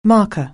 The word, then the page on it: marker: anglais